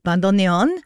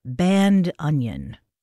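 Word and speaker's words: bandoneón: And in order to make it easier for non-Spanish speakers to pronounce, he very graciously invites you to say bandoneon. Bandoneon. There you go.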